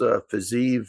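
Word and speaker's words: Fiziev: Loved the way he fought the last fight against Fazeev. I'm sure I'm pronouncing his name wrong, but nope, got it right.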